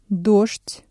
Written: дождь

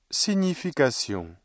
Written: signification

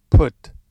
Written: put

